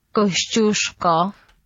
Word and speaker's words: Kościuszko: And it's difficult for me to say the name, so I've checked it out on Google Translate. This is how you say it. – Kościuszko.